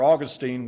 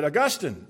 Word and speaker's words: Augustine: After Augustine was, or Augustine as some of you might pronounce it, after Augustine was converted, he came out of a very immoral life.